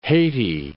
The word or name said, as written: Haiti